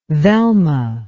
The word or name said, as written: velma